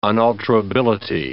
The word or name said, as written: unalterability